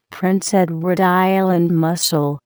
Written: Prince Edward Island mussel

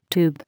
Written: tube

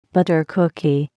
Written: butter cookie

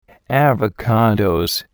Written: Avocados

